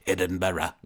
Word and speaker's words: Edinburgh: It's spelled Edinburgh, but it's pronounced Edinburgh. – Okay. – That's how they say it.